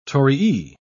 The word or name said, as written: torii